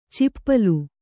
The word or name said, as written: ಚಿಪ್ಪಲು